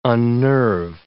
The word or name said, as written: unnerve